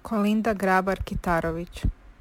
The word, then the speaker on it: Kolinda Grabar-Kitarović: Kolinda Grabar-Kitarović.